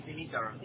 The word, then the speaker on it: Dimitar: My name is Dimitar. D-I-M-M-I-T-A-R. – I'm sorry. How do you spell that? – It is D-I-M-I-T-A-R.